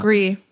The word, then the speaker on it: Gris: So this is coming from Gris. – Hello. No, Gree. – Gree, sorry. Hello. They said, hello. Menopip on Instagram. It's at MENEMONIP. Don't know how to pronounce that. I believe I have had some online encounters on Instagram. You have, we follow each other, and Twitter, but maybe you don't remember me. I also might have changed my username since, which is totally fine. You can refer to me as Gris, pronounced like French, so S is silent, on your podcast.